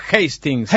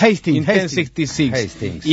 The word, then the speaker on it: Hastings: Pronunciación Hastings. – Hastings.